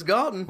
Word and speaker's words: Galton: Yeah, rhymes with Dalton, but it's Galton.